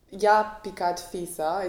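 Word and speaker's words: i-a picat fisa: It's actually pronounced Ja picat fisa.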